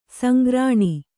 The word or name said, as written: ಸಂಗ್ರಾಣ